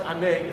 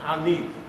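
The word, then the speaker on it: ani (אני): But someone said, hey, it's not pronounced ane, it's pronounced ani.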